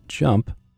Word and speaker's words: JMP: JMP, pronounced as JUMP,